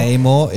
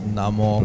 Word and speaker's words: Namor: It's Namor. Not Namor, it's Namor.